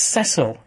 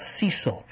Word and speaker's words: Cecil: once again echoes the line that even Phil Leonis has actually said, referring to it as Cecil. Phil, I've said before, and when you see Mandy, she is a Renaissance woman, but it's Cecil, not Cecil.